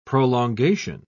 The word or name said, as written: prolongation